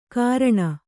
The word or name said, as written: ಕಾರಣ